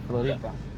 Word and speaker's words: Floripa: Floripa. – That's it. End of it. We in Floripa. – We're in Floripa. – No, Floripa. – We're in Floripa. – Floripa. – There you go, Floripa.